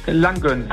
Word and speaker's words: Langgöns: Hör mal, heißt das Langgöns oder Langgöns? – Langgöns. – Sehr schön, dann habe ich es jetzt 20 Jahre richtig gesagt.